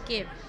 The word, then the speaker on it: scoib: It's a traditional Irish basket, which is a historical basket called SCOIB, and it's pronounced skib.